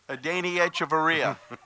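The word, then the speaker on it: Adeiny Hechavarria: Well, when you have somebody, how do you pronounce the Rays shortstop's name? – Adani Echevarria. There you go.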